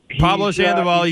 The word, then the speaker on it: Pablo Sandoval: It kind of reminds me of, and I hope I say this right, Paulo Sandoval. – Pablo Sandoval, you did say that right, but how does he remind you of Pablo Sandoval? – Out of shape.